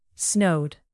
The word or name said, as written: snowed